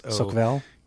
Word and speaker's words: Soquel: It's Soquel. – Q-U-E-L.